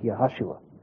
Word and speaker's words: Yeshua: His name in Aramaic was Yeheshua, which is the later corruption of the Hebrew word Joshua, which was not pronounced Joshua either. It was pronounced Yahashua.